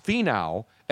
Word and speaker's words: Finau: His name is Tony Finau, but it's pronounced Finau, F-E-E-N-O-W, but it's spelled F-I-N-A-U. – F-I-N-A-U. – Tony F-I-N-A-U qualifies for the Masters, and in the par three today, gets a hole-in-one.